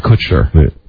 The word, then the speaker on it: Kutcher: Demi Moore and Ashton Kushner showed up. – I love you. I love the way that you pronounce his last name. – And I can say it correctly. Ashton Kutcher.